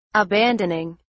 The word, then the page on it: abandoning: English